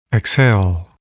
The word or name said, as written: exhale